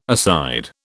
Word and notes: aside: British English

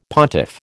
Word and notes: pontiff: American English

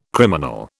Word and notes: criminal: British English

